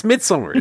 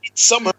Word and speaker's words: Midsommar: It's Midsommar, is what it's called. – It's just, yeah, they say it's weird because I see loads of people pronounce it like with a hard A.